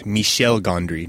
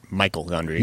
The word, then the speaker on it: Michel Gondry: Since we're focusing so heavily on pronunciation, I thought I should point out that it's Michel Gondry, not Michael Gondry.